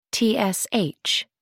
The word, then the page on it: tsh: American English